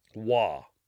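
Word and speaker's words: Waugh: Dorothy, it's W-A-U-G-H. And I thought, well, maybe it's like... You know, if it's you know, some nationality, like Vaux or something. But no, she's from Westmoreland, England. So it's not going to be any type of weird pronunciation. Stephanie looked it up the other day, and it came up as Wah, Dorothy Wah.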